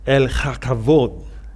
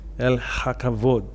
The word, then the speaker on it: El Hakavod: So the Hebrews, I'm sure they will pronounce it like uh, El Chakavod, you know? And that is, um, it still doesn't help you. okay, just just spell it Haka, H A K A V O D. Okay, El Hakavod.